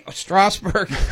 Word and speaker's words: Strasburg: 753 South Worcester Avenue there in Strasburg. Right? Strasburg. – We knew what you meant. He was going on the German pronunciation.